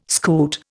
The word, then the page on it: scoot: Indian